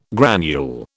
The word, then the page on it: granule: British English